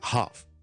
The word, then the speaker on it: half: 今日同大家讲咧，串字里面有嘅 L 音而唔需要读嘅，最常听到咧，大家都知啦，half， H A L F，一半，我哋个 L 系唔读啦。